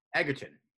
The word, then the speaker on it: Egerton: No, Egerton. – Egerton. – That's how you say it.